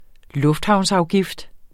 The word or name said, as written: lufthavnsafgift